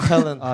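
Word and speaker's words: Köln: 켈른 아 예.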